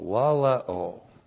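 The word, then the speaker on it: laleo (λαλέω): L-A-L-E long O, laleo.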